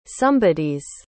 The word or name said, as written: somebodies